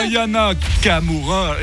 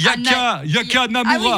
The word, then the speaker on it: Aya Nakamura: J'avais dit attention sur la prononciation. Hein. Aya Nakamura. C'est Nikos qui n'arrive jamais à le placer. Hein. – Comment il avait dit? – Il avait dit Yaka Namura.